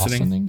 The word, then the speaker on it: Ossining: I might have pronounced Ossining. – I think it's Ossining, New York.